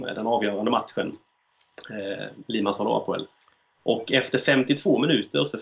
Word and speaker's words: Apoel: Hur fan uttalar man det? – Apoel. Ja.